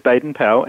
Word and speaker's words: Baden-Powell: Tell me how you picked this quote from Lord Baden-Powell, or do you pronounce it Baden-Powell? How should I say it? – It is Baden-Powell.